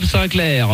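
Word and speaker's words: Sinclar: Non, Bob Sinclair. – Ça s'appelle Jim Tonic. Et il y a ensuite, ça se prononce comme Sinclair pour qu'elle puisse comprendre. On va faire Sinclair. C'est S-I-N-C-L-A-I-R.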